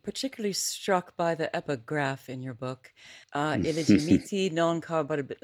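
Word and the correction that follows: Illegitimi non carborundum: Okay, I'm going to say it correctly, okay? Say it for me. – Illegitimi non corroborandum.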